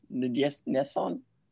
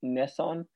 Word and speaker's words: N'Guessan: It's N-G-U-E-S-S-A-N. I've heard it pronounced N'Guessan and Nesson. I will be saying Nason because I hear that used more often. Or sorry, I'll be using N'Guessan because I will actually.